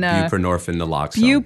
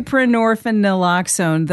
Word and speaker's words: buprenorphine naloxone: Buprenorphine naloxone. – Buprenorphine naloxone.